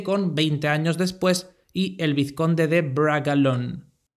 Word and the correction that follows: Bragelonne: Como soy un desastre pronunciando nombres extranjeros, especialmente si estos son en francés, le he pedido a Siri que me corrija. Bragelonne. Gracias, Siri.